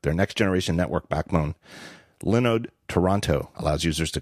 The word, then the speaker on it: Linode: Linode uh, lets you instantly deploy and manage SSD servers in the Linode cloud. I used to pronounce it Linode because that's what it looks like.